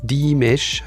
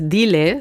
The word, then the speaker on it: Diles: Dimash heißt das Stück. Habe ich es richtig ausgesprochen? – Nee, hast du falsch. – Nein, falsch. – Das heißt Diles.